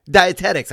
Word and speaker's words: dietetics: Dietetics. I always say it wrong. – Yeah, dietetics. – I say dietetic. Yeah. – I add a T in there.